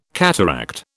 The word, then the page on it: cataract: British English